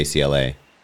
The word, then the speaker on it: Ciele: And I feel like people know it as, the different pronunciations, but people know if you say Ciele.